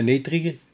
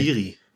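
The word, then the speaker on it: Neytiri: der eigentlich der Mann wäre von der Netri, nee, ich kann mir den Namen, ne kann das aussprechen. Netiri.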